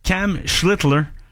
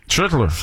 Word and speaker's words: Schlittler: Et il se bat présentement avec un duel impitoyable, je dirais, avec Cam Schlittler. – Schlittler. J'aime ça de la manière que tu l'as très bien prononcé.